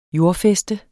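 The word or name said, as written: JORDFÆSTER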